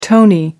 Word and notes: tony: American English

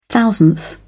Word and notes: thousandth: British English

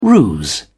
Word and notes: ruse: British English

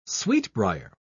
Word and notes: sweetbrier: British English

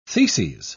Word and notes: theses: British English

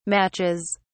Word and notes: matches: British English

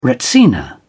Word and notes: retsina: British English

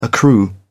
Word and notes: accrue: British English

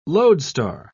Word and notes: loadstar: British English

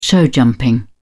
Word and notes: showjumping: British English

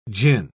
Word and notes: jin: British English

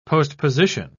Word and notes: postposition: British English

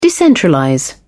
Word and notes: decentralize: British English

